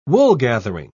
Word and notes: woolgathering: British English

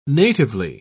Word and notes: natively: British English

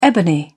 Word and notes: ebony: British English

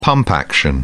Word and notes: pump action: British English

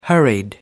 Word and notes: hurried: American English

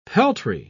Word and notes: peltry: British English

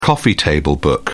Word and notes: coffee-table book: British English

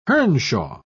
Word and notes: hernshaw: British English

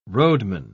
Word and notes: roadman: British English

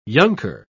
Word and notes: younker: British English

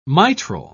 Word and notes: mitral: British English

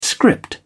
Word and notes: script: British English

